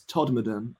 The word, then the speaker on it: Todmorden: I called it Todmorden, but it's Todmorden, I've been told by a mildly aggressive local via Instagram.